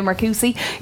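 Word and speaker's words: Marcoussis: I remember we were in Marcoussi, is that good pronunciation? Yeah, Marcoussi in France, which is the base of French rugby.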